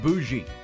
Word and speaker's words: bougie: And then the word bougie. Because it's actually B-O-U-G-I-E. It's pronounced B-O-O-J-E-E. Bougie. Like Ty's kind of bougie.